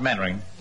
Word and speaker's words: Mainwaring: You must be Mainwaring. – Oh, how do you do, Colonel? Actually, it's pronounced Mannering. – And why the hell do you spell it Mainwaring?